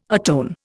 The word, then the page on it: atone: Indian